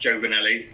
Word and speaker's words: Giovnilli: For those of you looking at my name on the screen, it's pronounced Giovnilli, but don't worry if you get that wrong.